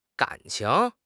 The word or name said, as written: gan3 qing2